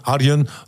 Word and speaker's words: Arjen: Nee, kijk, Robben is geen legende Arjen Robben. – Nee, mag Arjan zeggen.